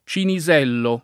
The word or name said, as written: Cinisello